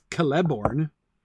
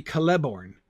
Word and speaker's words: Celeborn: Nobody says Celeborn. – No. – Galadriel's husband? I mean, technically, if the stress in elvish names is always on the second to last syllable, then it should be Celeborn.